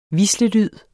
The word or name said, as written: hvislelyd